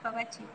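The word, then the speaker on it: Pabachi: Eh, siento que digo, se llama Pavachi, Pavachi muy bien. – ¿Cómo se escribe? Eh, P-A, B grande A, C-H-I, Pavachi.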